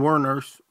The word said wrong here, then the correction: Wuornos: Eileen Wuornos, From Monster.